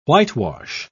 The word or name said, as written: whitewash